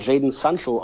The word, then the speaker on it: Jadon Sancho: Er wird ihn wahrscheinlich auch Jadon Sancho aussprechen. Mir ist nichts anderes geläufig.